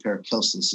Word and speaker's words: Paracelsus: You'll hear him called Paracelsus. I think Paracelsus is the right pronunciation, but he shows up and he introduces this technology of spigeria, which is inherently involved with plants.